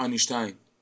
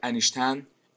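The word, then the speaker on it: Einstein: آلبرت اینشتین به تلفظ صحیح آلمانی ولی معروف به انیشتن، انیشتین، اینشتین در ایران.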